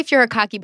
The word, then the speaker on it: furikake: And then I've been topping the whole thing with furiyaki. – Oh, that, yeah. Mm-hmm. – Is that how you say it? – I say furikake, but I've never heard anybody with any authority on the topic pronounce it.